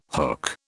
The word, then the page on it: hook: British English